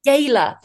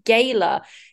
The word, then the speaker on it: gala: You say gala. Because I say gala. And it's so weird because you say it as though it's spelled G-A-Y-L-A. And I say it as though it's spelled G-A-R-L-A.